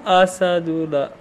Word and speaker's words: Asadullah: Asadullah. A-S-A-D-U-L-L-A-H.